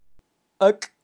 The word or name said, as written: ak